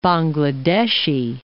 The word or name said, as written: Bangladeshi